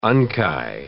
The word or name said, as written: unci